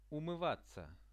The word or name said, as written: umyvatsia